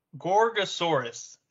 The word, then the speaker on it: Gorgosaurus: So anyways, Gorgosaurus. That's how you say it.